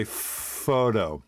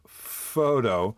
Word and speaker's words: Phhhoto: All right, I don't know how to say this. It's spelled P-H-H-H-O-T-O. So I'll just say photo.